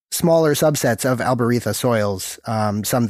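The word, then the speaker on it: albariza: When I'm studying, like, Jerez and the soils around Jerez, the big important one to remember is the Albarita, spelled with a Z but pronounced with a lisp, because, you know, Spanish.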